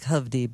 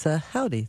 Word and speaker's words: Howdy: So it looks like Hovdy, but it's Howdy. That's how they pronounce it.